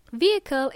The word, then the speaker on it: vehicle: Vehicle is a thing used for transporting people or goods. There is a silent H in this word. Vehicle is spelled as V-E-H-I-C-L-E, and we only say vehicle.